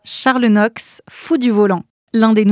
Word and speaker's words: Noakes: Charles Knox s'est fait un nom. Ça s'écrit N-O-A-K-E-S. Ça se prononce Knox, c'est anglais, il est né là-bas.